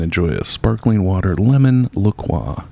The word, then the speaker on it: La Croix: It's pronounced La Croix.